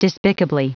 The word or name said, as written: despicably